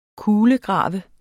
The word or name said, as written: KULEGRAVES